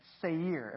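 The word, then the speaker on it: Seir (שַׂר): This word prince, the Hebrew word for prince is this word called, it's pronounced this way, Seir. Everybody say Seir.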